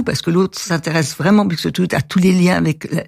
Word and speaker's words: Buxtehude: Alors j'espère que je vais pas écorcher son nom, qui est Buxtehude, c'est ça? – Buxtehude. Voilà, Buxtehude, qui était tout à fait en or vers Hambourg et tout voilà.